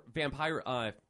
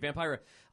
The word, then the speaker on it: Vampira: Vampira.